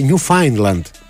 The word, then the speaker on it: Newfoundland: Για το φίλο που λέει Ακτύπη, επειδή έχεις πάει πολύ πέρα από το Proficiency και σ' αρέσουν αυτά, το Newfoundland προφέρεται Newfoundland πληροφοριακά.